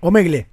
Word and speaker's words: Omegle: De hecho, había una aplicación anterior que era también con Omegie, este tipo de sistema. – Pero la cerraron a claro, esa, ¿no? Omegie, que era, no sé cómo se pronuncia. – Claro. Omegle. – Omegle. Omegle.